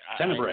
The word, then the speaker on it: Tenebrae: I don't even know if I'm saying that right. – Tenebrae. – I know that it is Tenebra, you know me, I like Tenebrae. – So it's a bra. Tenebrae.